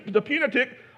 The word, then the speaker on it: Pentateuch: They could quote the first five books of the Pentateuch, or however you say that thing. Somebody say it. I said it wrong.